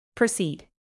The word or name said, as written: proceed